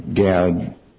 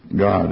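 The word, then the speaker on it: God (גד): But that word troop, using the King James, the word fortune in the NASU, that word is that gimel dalet, the gad, that's written gad in some translations like this next one I'll read, but is actually pronounced God according to Strong's and other sources.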